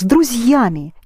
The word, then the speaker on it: друзьями: Pronounce together. С друзьями. You keep the z soft because there is a soft sign there.